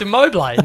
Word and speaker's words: Immobile: I don't know how to say his name properly. Immobile. – No, it's Immobile. No.